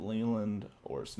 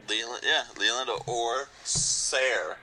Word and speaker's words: Leland Orser: Leland Orser. I think I'm probably saying his name wrong. Let me double check it. Leland Orser. No, that's right. – Leland Orser.